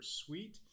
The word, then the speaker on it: CEWT: We pronounce that CEWT or SWEET.